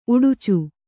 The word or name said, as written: ಉಡುಚು